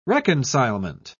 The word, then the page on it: reconcilement: British English